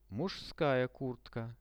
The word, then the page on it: muzhskaya kurtka: Russian